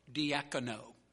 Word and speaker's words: diakoneo: That word serve in the Greek language is spelled D-I-A-K-O-N-E-O, diakono.